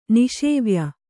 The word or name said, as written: ನಿಷೇವ್ಯ